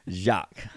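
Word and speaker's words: Jaques: Yes, it's J-A-Q-U-E-S. – Jacques.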